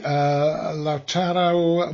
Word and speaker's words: Lautaro: Lautaro Martinez, have I pronounced his first name anything like it should be? – 100%, 100% once again, Martin.